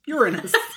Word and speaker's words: Uranus: I'm pronouncing that correctly for anybody who's like, she just doesn't want to say it. – I'm an adult. Must be. – We're like avoiding eye contact. It's Uranus. Even though the students never believe me and they're always like, you just don't want to say it. And I'm like, no, I actually, it's pronounced Uranus.